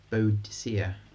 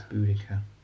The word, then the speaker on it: Boudicca: And she's incorrectly pronounced as Boadicea. – Yeah. – But it's Boudicca.